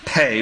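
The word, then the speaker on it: pay (פ): It is spelled P-E, but it is pronounced pay.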